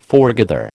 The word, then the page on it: foregather: American English